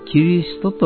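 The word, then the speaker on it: 基督: これを漢字で、基礎の基、監督の徳と書いて、キリストと読みます。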